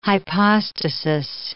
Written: hypostasis

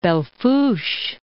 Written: Belle Fourche

